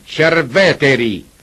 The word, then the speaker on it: Cerveteri: E Cerveteri significa appunto Cere antica, e si deve pronunciare Cerveteri e non Cerveteri, perché in lingua latina l'aggettivo vetus fa al genitivo veteris e non veteris.